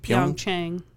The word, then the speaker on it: Pyeongchang: Pyeongchang.